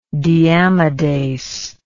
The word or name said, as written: deamidase